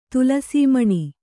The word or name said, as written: ತುಲಸೀಮಣಿ